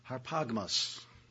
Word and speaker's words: harpagmos: It's H-A-R-P, like harp, the musical instrument, and then add an A-G-M-O-S, harpagmos. I'll say it like that.